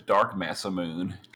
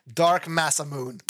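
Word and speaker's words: Dark Masamune: Hey, how do you pronounce it? Like, what is it, Dark? – That is Dark Massa Moon. – Dark Massa Moon.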